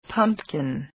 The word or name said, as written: pumpkin